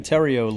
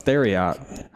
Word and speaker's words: Theriot: We are in Terrio, Louisiana. I would have not said that right. Terrio? Yeah, I thought it was Theriot.